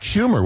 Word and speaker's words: Schumer: I believe that was Chuck Schumer, was it not? – Yes. Yeah. The senator's name rhymes with rumor.